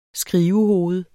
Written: skrivehoved

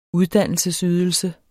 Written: uddannelsesydelse

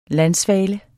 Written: landsvale

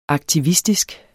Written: aktivistisk

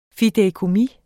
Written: fideikommis

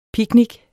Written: picnic

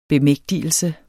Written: bemægtigelse

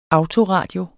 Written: autoradio

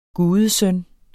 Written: gudesøn

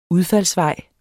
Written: udfaldsvej

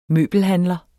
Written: møbelhandler

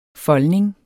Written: foldning

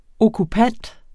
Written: okkupant

